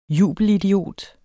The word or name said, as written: jubelidiot